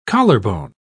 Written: collarbone

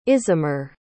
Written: isamar

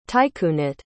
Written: tycoonate

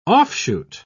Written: offshoot

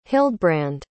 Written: hildbrand

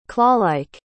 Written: clawlike

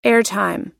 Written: airtime

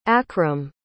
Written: akram

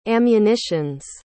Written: ammunitions

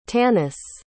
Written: tannis

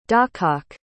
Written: dawcock

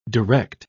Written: direct

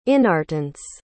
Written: inertance

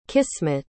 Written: qismat

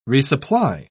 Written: resupply

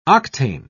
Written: octane

